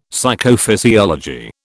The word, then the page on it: Psychophysiology: British English